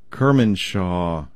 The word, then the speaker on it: Kermanshah: Researchers from Kermanasha University. I know I butcher these names. I'm so sorry. So, Kermanshah University of Medical Sciences in Iran,